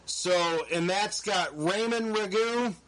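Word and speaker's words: Rougeau: The other team is the I might butcher this, the Ragu Brothers? – Ragu.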